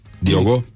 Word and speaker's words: Diogo: Solis vocal și chitară și uh, Diego, Diego, Diogo, Diogo, Diogo e basist, dar... Diogo.